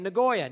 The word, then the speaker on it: Nagoya: It was this town in Japan named Nagoya.